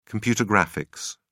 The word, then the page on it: computer graphics: British English